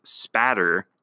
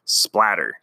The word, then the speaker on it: spatter: I actually talked with a Michigan State police officer, and that is the correct way to pronounce it is spatter, S-P-A-T-T-E-R. It's not splatter, so there's a lesson learned.